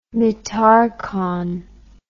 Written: metarchon

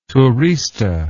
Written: tourista

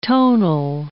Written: tonal

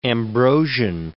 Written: Ambrosian

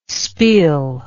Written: spiel

